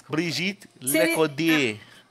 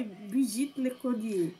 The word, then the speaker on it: Brigitte Lecordier: O Boji, o dublador do Boji ganhou na melhor performance de voz francesa, que foi o Brigitte Lecordre. Alguém sabe francês? A minha pronúncia? – Não. – Então, Brigitte Lecordier. Ah. – Eu fiz francês, acho que tá certo isso daí. Brigitte Lecordier.